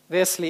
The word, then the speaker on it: Весли: Весли или Уэсли, как его по-разному по-русски произносят.